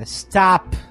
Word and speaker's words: stop: O único som que geralmente as pessoas pronunciam correto nessa palavra é o T. Porque o S, a gente coloca uma vogal no começo. – E stop. – Exatamente. A gente coloca uma vogal no final. E esse O, na verdade, não é o mesmo O que a gente pronuncia.